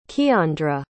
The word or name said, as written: keiandra